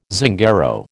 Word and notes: Zingaro: American English

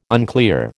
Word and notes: unclear: American English

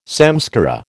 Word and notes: samskara: American English

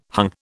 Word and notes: hung: British English

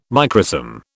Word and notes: microsome: British English

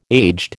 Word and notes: aged: American English

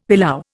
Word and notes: pilau: Indian

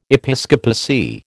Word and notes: episcopacy: American English

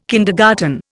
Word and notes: kindergarten: Indian

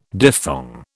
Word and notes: diphthong: British English

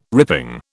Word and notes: ripping: British English

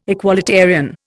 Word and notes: equalitarian: Indian

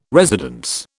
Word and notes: residence: British English

